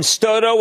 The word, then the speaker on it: Stotto: Your position is just like Ben Stotto, which, by the way, rhymes with photo, who is our research director, and he likes Yeti very much, and I am with you.